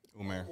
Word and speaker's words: Umar: Basically, Umar.